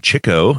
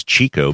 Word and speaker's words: Chico: It's often mispronounced as Chico, but it's actually Chico. That's kind of a little rant that I have. A lot of people pronounce it Chico, but that would be the Hispanic version of the word. And he was Italian, and the common way of pronouncing it would actually be Chick.